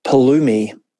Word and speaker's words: Pulumi: There's a new dev-related platform thingy startup called Pulumi. P-U-L-U-M-I. I think I'm pronouncing it correctly. – Looks like it, yeah.